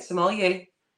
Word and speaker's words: sommelier: Sommelier. Is that how you say it? – You said it right. Sommelier.